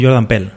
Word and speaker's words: Jordan Peele: Yo digo Jordan Peele.